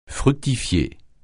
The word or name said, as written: fructifier